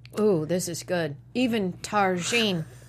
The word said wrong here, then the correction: tagine: I said it wrong. – Tagine! – Damn it, God! – Are you Polish? What's happening? – Two white people on the show. You gotta throw in the silent J. – Tagine. Yeah.